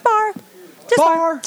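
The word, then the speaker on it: barre: It has an R and E on the end. – We even tell our girls. They're bar babes. – My son's name is Barry. – Okay. – And it has two syllables. Barry. Like bar. – Barry. – Barry. I kept calling it Barry. I didn't know how to call it. – Bar. Just bar.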